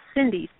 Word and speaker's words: Cyndi: So it's F as in Frank, E, which stands for iron, of course, and then hope, H-O-P-E. And my first name is spelled funny. It's Cindy, C-Y-N-D-I.